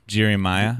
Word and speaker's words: Jeremiah: Jeremiah. – They a lot of people go Jer-I, like they think it's spelled J-E-R-I, but it's J-E-R-E-M-I-A-H.